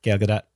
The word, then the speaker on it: Gal Gadot: She says, hi, I'm Gal Gadot. And she said it really quickly, so like the T kind of like drops off, but it's there.